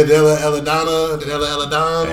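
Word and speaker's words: Delle Donne: Hey, how do you say her girl name? – Man. – Della Donna. – Yeah, Della Donna.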